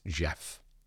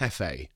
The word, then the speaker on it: Gef: No, it's Jeff with a G, because then I've written brackets J-E-F, pronounced Jeff. Jeff, not Hefe.